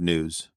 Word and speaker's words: gnus: Do you know what a new is? It's like an antelope. It's like an antelope. It's spelled G-N-U-S.